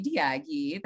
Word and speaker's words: Daiagi: My name is Gabi Daiagi.